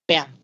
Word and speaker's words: Banff: This is how you pronounce it, Banff.